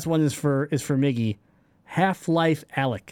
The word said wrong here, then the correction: Alyx: Did I say it right? Alex. Alex. Oh my bad.